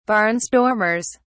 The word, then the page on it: barnstormers: British English